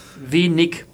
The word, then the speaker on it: wenig: Es ist nämlich nicht ich, sondern äh, am Wortende im Hochdeutschen immer ein Ich, also König, Honig, wenig.